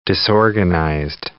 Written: disorganized

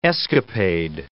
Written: escapade

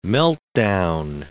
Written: meltdown

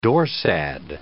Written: dorsad

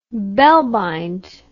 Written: bellbind

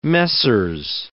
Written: Messrs.

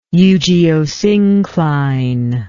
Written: eugeosynclinat

